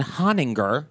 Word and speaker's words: Hoeninger: And my legal name, just so you know, is Scott Allen Honinger, H-O-E-N-I-N-G-E-R. But I'm about to be a little crude for the listeners here. People often mispronounce my name. – Uh-huh. – And they see Ho and then they forget that second N in the N-I-N-G-E-R, and it becomes a derogatory, very racist word.